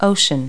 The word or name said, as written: ocean